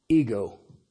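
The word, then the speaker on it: ego (ἐγώ): It's always translated as a personal pronoun, and it's spelled epsilon gamma omega. We pronounce it ego.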